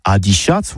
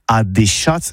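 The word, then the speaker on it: adishatz: Eh oui, Adichat, que c'est prononcé au tabé, Adichat, voire Adéchat, surtout en Gascogne toulousaine.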